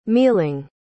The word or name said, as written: mealing